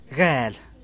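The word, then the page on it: gel: Teotitlán del Valle Zapotec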